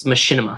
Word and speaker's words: Machinima: I think I'm saying that right. – I think it's Machinima. – Machinima? Sure.